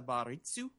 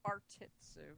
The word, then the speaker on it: Bartitsu: She's doing the, how do you pronounce it? The baritsu? – Bartitsu.